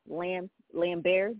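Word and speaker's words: Laimbeer: And then my number one overall dirty player to me of all time in the NBA was Bill Lambert. Did I say that right? – Yep. – Yes, you did.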